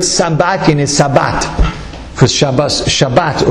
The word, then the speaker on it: Shabbat: Well, stop saying Shabbos like such an Ashkenazi. Maybe say like a Svardi, a good Svardi. How do you say Shabbos like a good Svardi? Shabbat. What does Shabbat sound like? It sounds like Sabbat.